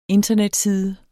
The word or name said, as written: internetside